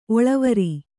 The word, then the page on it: ಒಳವರಿ: kannada